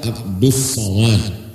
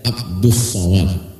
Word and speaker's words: Abdus Somad: Kalau translasi Indonesia Abdus Somad bacanya, tapi tulisnya banyak Abdul Somad. Cara bacanya Abdus Somad.